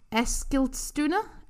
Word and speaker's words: Eskilstuna: Eskilstuna? – Yeah.